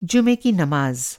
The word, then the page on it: जुमा: جُمعہ